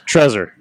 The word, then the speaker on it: Trezor: All right, first off, Trezor. It's Trezor.